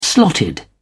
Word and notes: slotted: British English

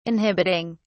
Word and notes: inhibiting: British English

